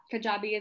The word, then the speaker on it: Kajabi: And so I saw you use the platform Kajabi. Is that how you say it? – Yeah, Kajabi is the platform I use.